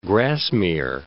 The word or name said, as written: Grasmere